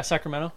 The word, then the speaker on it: Sacramento: Yeah, Sacramento.